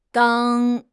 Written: dang1

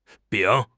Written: 丙

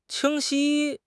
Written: 清晰

